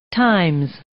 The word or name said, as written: times